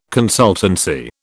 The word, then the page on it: consultancy: British English